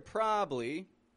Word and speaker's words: probably: So, of course, the word probably, or probably, is spelled P R O B A B L Y. P R O B A B L Y. This is spelled as a three syllable word.